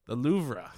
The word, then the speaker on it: Louvre: The Louvre, and I don't even know how to say it properly. – The Louvre.